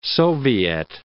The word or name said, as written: soviet